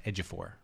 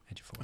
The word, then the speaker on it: Ejiofor: I think it's pronounced Ejiofor. Ejiofor. – I think so. – Not Ejiofor.